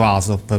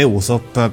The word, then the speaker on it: Usopp: Nel, nella versione italiana del cartone animato è pronunciato Asop, però la pronuncia giusta sarebbe Usopp perché viene da Uso, che in giapponese significa bugia,